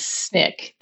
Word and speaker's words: SNCC: And it was specifically a tool that was used in the 1960s by the Student Nonviolent Coordinating Committee, SNCC, which is also known as SNCC. That's the way you pronounce it.